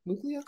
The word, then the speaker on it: Muglia: Did I pronounce that right, by the way? Muglia? – Good enough. Muglia, close enough.